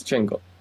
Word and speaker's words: Django: AJ, tell us about Mr. Django. – No, remember the D is silent. – Yes, Django.